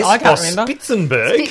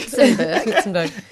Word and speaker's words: Spitzenberg: Spitzenberg. – Spitzenberg.